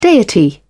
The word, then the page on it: deity: British English